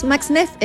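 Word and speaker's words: Max-Neef: Y me dijo, yo soy amiga de la Magdalena Magnif, que me acabo de dar cuenta que se dice así, pues yo decía Magdalena Magnif. – No, es Max Neff, ella le dice Max Neff.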